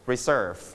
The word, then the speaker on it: reserve: you stress on the second syllable Reserve